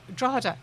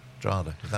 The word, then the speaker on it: Drogheda: Is that how you pronounce it? – Drogheda. Spell that. – Drogheda. – Drogheda. I was born there.